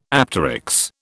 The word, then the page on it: apteryx: British English